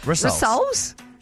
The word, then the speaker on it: rissoles: I felt like you were saying that funny, but that is how you say it. – Rissoles? – Rissoles.